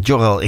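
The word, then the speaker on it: Görel: En goed, ik heb eigenlijk ABBA gekozen vanwege het overlijden van Jorel Christina Johnson. En Jorel, ik schrijf je eigenlijk met een G, maar in het Zweeds spreek je de G als een J uit.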